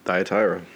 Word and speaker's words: Thyatira: This week, Thyatira? – Thyatira. – That's how you say it? – Yeah.